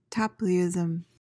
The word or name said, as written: Tapleyism